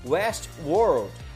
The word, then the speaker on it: Westworld: Westworld.